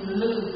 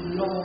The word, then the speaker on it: lư: Miền Bắc chúng ta đọc là lư hương xạ nhiệt, còn miền Nam thì thiên âm và đọc là lô hương xạ nhiệt.